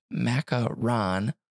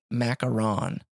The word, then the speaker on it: macaron: Which macaron pronounced macaron, where ron rhymes with gone. So macaron.